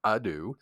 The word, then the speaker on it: ado: So, without further ado, I always say that wrong. Usually I say to do, and Ellison always corrects me. It's ado.